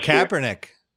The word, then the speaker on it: Kaepernick: From oh, Kaepernick. – Year. Yeah, Kaepernick.